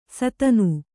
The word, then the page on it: ಸಾದಣ: kannada